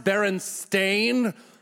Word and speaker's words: Berenstain: I grew up with the Berenstain Bears, and it's pronounced Berenstain.